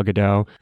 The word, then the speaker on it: Gadot: And then at the very end, they had Gal Gadot. – Yeah. – Or Gadot, I think. – Gadot. Yeah. – Godot, whatever. – I know you pronounce the T.